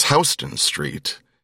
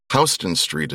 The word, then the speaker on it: Houston Street: For instance, the one that has tripped up so many of my colleagues is Houston Street in southern Manhattan. Houston Street is spelled like the city Houston. They are not pronounced the same way.